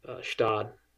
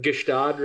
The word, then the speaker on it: Gstaad: Yeah, everybody goes like Gstaad, right? That's like the, but it's just Stad, and it is picturesque.